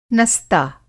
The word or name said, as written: नस्ता